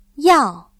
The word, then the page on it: 药: yào